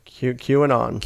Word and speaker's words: QAnon: Yeah, QAnon.